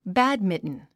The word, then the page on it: badminton: bǽdmintn; バァドミントン